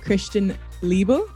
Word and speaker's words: Christian Liebel: And our guest, the most wonderful, Christian Liebel.